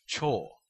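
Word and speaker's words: cho (超): But in the own reading, which is drawn from Chinese, it is pronounced cho.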